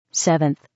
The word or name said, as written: 7th